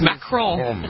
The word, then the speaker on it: Macron: Macron.